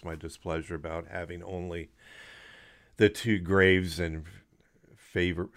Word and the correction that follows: Fravor: I was mispronouncing it's Favor. It's, what is it? – Fravor? – Fravor. Yeah. Yeah, it's Fravor.